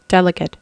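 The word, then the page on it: delegate: noun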